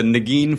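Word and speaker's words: Negin: So how is it that – and by the way, am I pronouncing your leading lady's name right? Is it Nurgen? – You're not, but no one has ever pronounced it correctly. – Tell me. – It's a Nagin Farsad.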